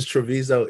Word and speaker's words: Treviso: Oh, it is Treviso, Italy.